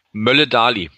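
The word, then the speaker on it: Møller Dæhli: Danach sah es zur Halbzeit nicht aus, nachdem äh, Mats Möller-Deli, habe ich das richtig ausgesprochen? – Mölle Dali. – Mölle genau, Dali, okay, ja.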